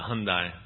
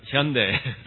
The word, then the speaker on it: Hyundai: And most people here pronounce it Hyundai, but it's Hyundai.